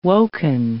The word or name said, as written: woken